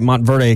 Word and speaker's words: Montverde: This is why you go to a place like Monteverde Academy. I say that right, Montverde Academy.